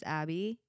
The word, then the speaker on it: Abbey: My name is Abby. A-B-B-E-Y is how it's spelled.